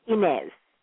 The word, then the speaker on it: Inez: No, and in Jamaica, that spelling is pronounced Inez. – Oh, Inez.